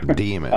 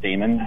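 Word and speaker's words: daemon: So you know, Americans often pronounce it Damon, but the author is British, and he meant it to be demon, but I can't really go into the literature about that.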